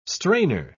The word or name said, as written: strainer